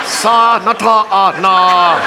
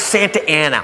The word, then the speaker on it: Santa Ana: Sa-na-ta-na. How? It's Santa Ana. What says Santa Ana? Look, I know I didn't finish school, but that frickin' says Sa-na-ta-na. They pronounce it Santa Ana.